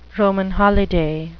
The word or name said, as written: Roman holiday